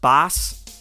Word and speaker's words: baas: Boss is originally a Dutch word, roughly pronounced baas, B-A-A-S, something like that.